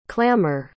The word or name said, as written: clamour